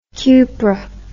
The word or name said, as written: cupr